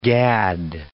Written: gad

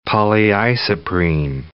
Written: polyisoprene

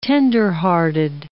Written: tenderhearted

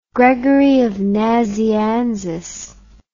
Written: Gregory of Nazianzus

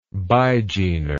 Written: bigener

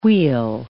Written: weal

